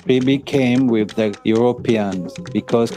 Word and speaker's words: Kribi: That's K-R-I-B-I, Kribi. But if you pronounce it, it's Karibe, right?